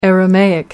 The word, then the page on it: aramaic: American English